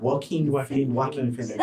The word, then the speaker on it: Joaquin Phoenix: Joaquin Phoenix.